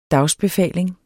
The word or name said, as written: dagsbefaling